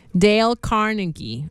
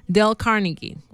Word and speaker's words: Dale Carnegie: Dale Carnegie. – Ahí está. Entonces ya no digamos del carnillero, no sé cómo es que decimos. Dale Carnegie. Creo que así es. Veamos de nuevo. – Dale Carnegie.